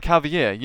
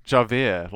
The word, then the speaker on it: Javier: Or, like Javier, you know, with the old Australian trick of spelt with a J said like an H, I've finally gotten used to it. At first I wanted to keep calling him Javier, like in Les Mis.